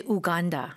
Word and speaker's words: Uganda: You know, people say Uganda, right.